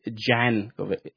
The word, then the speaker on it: Jankovic: I say Jankovic. That's the way she pronounced it. Actually, I found it in an interview.